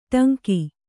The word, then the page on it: ಟಂಕಿ: Kannada